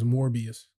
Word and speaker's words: Morbius: Like, how are you gonna make that appealing to— Did you say Morpheus? – Yeah. – His name is Morbius. – I said Morpheus, Jesus Christ. Yeah, Morbius.